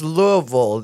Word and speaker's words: Louisville: And it's Louisville. They say it like retards. – Like Louisville? – Louisville. – It's Louisville. – Louisville. – Yeah. It's like, that's how they know if you're not from there.